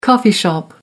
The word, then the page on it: coffee shop: American English